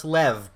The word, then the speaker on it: Lev: Lou. L-E-W. Well, it's Lev, but it's the Americans pronounce it Lou.